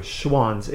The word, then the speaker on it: Schwan's: Schwann's. It was actually spelled S-C-H-W-A-N-S. – Thank you. – Schwann's. Yeah, nobody knew how to say it either.